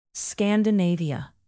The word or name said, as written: scandinavia